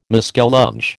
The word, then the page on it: muskellunge: American English